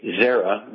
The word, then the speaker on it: Xera: Zyra, X-E-R-A, Zyra. The X is pronounced as a Z. Zyrtec. Okay, so it's one word, X-E-R-A-T-E-S-T.